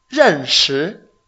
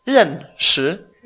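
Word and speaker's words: Renshi (认识): Renshi. – Renshi. – Exacto. R-E-N. Ren. Cuarto tono. Shi. – Segundo. – S-H-I. Segundo tono. Renshi. Por ejemplo, wo gei ni jie shao yi ge ni bu renshi de ren.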